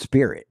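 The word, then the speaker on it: spiorad: But the actual correct Gaelic pronunciation, even though the word doesn't look like it's pronounced Spirit, the actual Gaelic pronunciation is in fact Spirit.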